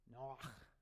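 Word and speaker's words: Noach (נח): Do you know how that's pronounced? Noach. Noach. Noach. All right? I'll write it up here for you. Looks like coach, but with an N, but it's Noach.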